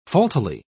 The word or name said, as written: faultily